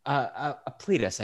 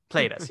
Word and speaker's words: Plautus: Plautus, I believe. – Cletus. Plautus? – Plautus? – Plautus. Plautus. – What you eat off of. – Plautus. Plautus, yes. – for Yes, the Roman playwright Plautus.